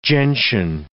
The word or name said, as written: gentian